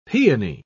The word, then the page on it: paeony: British English